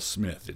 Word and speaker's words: Psmith: And I have to spell Smith. It's P-S-M-I-T-H, Smith. The P is silent.